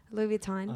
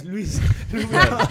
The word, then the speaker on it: Louis Vuitton: ¿Cómo se pronuncia en inglés? – Louis Vuitton. – Peruano, ¿cómo le dice? Luis. – Luis Vuitton. Luis Vuitton. – Luis Vuitton, claro.